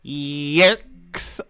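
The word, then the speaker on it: Yeerks: I do believe it's pronounced yerks because there are two E's. So it's more of a yerk.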